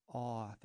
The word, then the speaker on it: Awh: Bats is the music project of Jess Awe, that's spelled A-W-H, and that's off of their recently released album called Blue Cabinet.